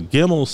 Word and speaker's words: Gimel: That is pronounced with a G. And so the Gimel section for tomorrow is the third section, third of 22, from verse 17 through 24.